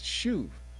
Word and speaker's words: shuv: When you go back and you look in the Hebrew, it's a word that's, that, that is pronounced shuv. And that word shuv is more of a financial term.